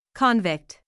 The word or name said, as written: convict